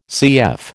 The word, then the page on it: CF: American English